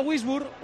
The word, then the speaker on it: Würzburg: Se escribe W-U diéresis, con diéresis esa U. Y luego R-Z-Burg. – Entonces, aquí en Salamanca se le conoce como Wiesburg.